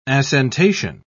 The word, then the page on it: assentation: British English